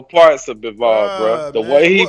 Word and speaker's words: Bivol: That's how you pronounce his name, Bivol? – Yeah.